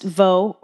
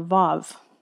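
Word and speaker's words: waw: The next letter, the next stanza, it's W-A-W in my Bible, and it is pronounced vo or vav, okay?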